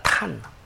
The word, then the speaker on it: Joktan: 욕단할 때의 이게 이제 발음이 영어 발음이나 히브리어 발음이나 전부 탄입니다. 탄. 단이 아니라 탄이에요. J-O-K-T-A-N. 영어로는 이렇게 되어 있고, 히브리어로도 탄으로 발음됩니다. 단이 아니에요. 그래서, 이제 이 사람들이 이러한 사람들이 이제 결국 하고 싶은 얘기는 뭐냐면 그런 거예요.